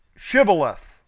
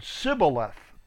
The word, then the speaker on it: Shibboleth: That came into play when they were asking the people of Ephraim that were crossing the creek, say Shibboleth. And they said Sibboleth. They had made it a sin. And that was a pronunciation difference, and they killed them because there was a war going on between the tribes at the time.